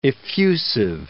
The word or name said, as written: effusive